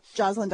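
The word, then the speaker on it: Joslin: Is a registered dietitian with the Jocelyn, am I pronouncing that right? – Yeah. – Diabetes Center? – Jocelyn Diabetes okay.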